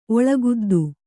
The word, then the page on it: ಒಳಗುದ್ದು: Kannada